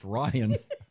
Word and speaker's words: Ryan: It's pronounced Ryan.